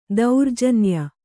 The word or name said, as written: ದೌರ್ಜನ್ಯ